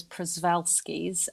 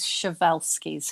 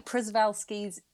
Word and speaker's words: Przewalski's: So this is, I had always thought it was Przewalski's. I have been told it is Przewalski's horse. So these are the ones that, although I have actually, I just double checked before we came on here because I wanted to make double sure I was saying it right. And apparently Przewalski's is how some people say it.